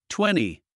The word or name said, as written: twenty